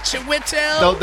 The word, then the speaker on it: Chiwetel: Chiwetel.